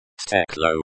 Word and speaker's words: стекло: Стекло. It is pronounced стекло. The e sounds like the e in bed. The k is a hard k sound, and the l is pronounced with a soft l sound.